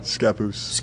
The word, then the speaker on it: Scappoose: And for those of you who don't know him, you're from Scappoose, Oregon. Did I say that right? – Scappoose.